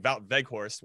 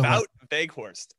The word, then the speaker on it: Wout Weghorst: Stars Wout Weghorst. And also, and it's spelled with a W, right? You pronounce the W's as V's, but whatever.